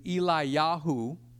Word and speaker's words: Eliyahu: Elijah is Eliyahu, which means my Elohim is Yahweh.